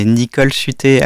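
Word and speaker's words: Nichole Sudtez: J'en rajouterai un d'un camarade qui a un nom d'emprunt qui est Nicole Suté. Alors, ça s'écrit Nicole, N-I-C-H-O-L-E, et Suté, S-U-D-T-E-Z, qui est en fait l'anagramme de « schizo en lutte ».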